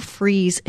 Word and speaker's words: Fries: You're listening to the Sue Freeze Show. Sue Freeze, spelled like fries, one word .com.